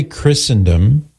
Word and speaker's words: Christendom: So we say Christendom with a silent T because some words in English have a silent T, like Christmas.